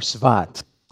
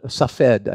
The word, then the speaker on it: Safed: pronounced different ways depending on what language you're trying to pronounce it in, but either Sefat or Svat. And what it's known for was one of the cities up on Safed, I should say, Safed or Svat, Z-F-A-T.